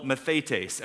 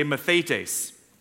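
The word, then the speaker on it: mathetes (μαθητής): In the New Testament, the Greek word that most of the New Testament was written in for disciple is called mathetes. Everybody say mathetes. Say it like you mean it.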